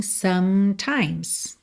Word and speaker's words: sometimes: Sem mistério aqui, lembra de não pronunciar as letras E pintadinhas de cinza em sometimes, tá?